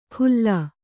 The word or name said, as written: ಖುಲ್ಲ